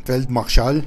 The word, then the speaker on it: Feldmarschall: Todo esto debía de hacerse en un ataque total, una orden que le fue encomendada al mariscal de campo, o como los alemanes lo pronuncian, Feldmarschall,